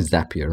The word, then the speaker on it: Zapier: probably zapier right because it zaps